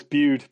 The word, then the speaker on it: Bude: No, it's Bude. It's B-U-D-E, so it's Bude. – It's like Bude, the cinema, yeah. – Yeah. What do they say? It's Bude like rude, which doesn't really make any sense.